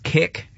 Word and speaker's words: Kik: And I assume it's pronounced Kik, K-I-K, is the actual app that he was using.